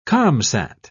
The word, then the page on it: comsat: British English